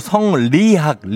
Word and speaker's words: 성리학: 아 망했다 성미학 내가 야 성미학은 답은 한 글자 차인데 성미학이 아니고 성리학 리.